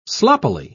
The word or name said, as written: sloppily